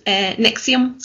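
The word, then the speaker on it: NXIVM: And it's written NXIBM, but it's pronounced Nexium, so it's not that hard to actually pronounce. – Nexium.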